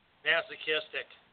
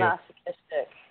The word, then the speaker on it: masochistic: How do you say this word? Masochistic tendencies? – Masochistic. – Thank you. – Masochistic.